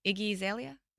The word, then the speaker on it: Iggy Azalea: I'm probably going to butcher her name. Iggy Azalea? – No? Oh, yeah. You didn't ruin it at all.